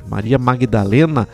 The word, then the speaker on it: Maria Magdalena: Na escuta, toca Maria Madalena. Um abraço. Maria Magdalena. Maria Magdalena, né? Que se escreve, inclusive.